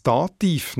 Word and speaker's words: Dativ: Jetzt stellt sich natürlich die Frage, wieso das Dativ nach dieser Logik nicht Dativ ausgesprochen wird, genau gleich wie Stativ. Auch das Wort Dativ kommt nämlich aus dem Lateinischen, und zwar von Dativus, mit der Betonung auf der zweitletzten Silbe. Tatsächlich werden die meisten Ivus-Wörter im Deutschen aber auf der ersten Silbe betont, egal wie viele Silben das Wort hat. Dativ, Akkusativ, Genitiv, Konjunktiv, Positiv, Negativ und so weiter.